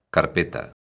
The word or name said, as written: carpeta